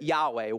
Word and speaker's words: Yahweh: God's personal name, God's personal name is, many people pronounce it Yahweh, Y-H-W-H.